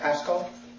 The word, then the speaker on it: pascho: How do you pronounce that? Pasco? Pasco? Pasco? P-A-S-C-H-O is suffer. 3958.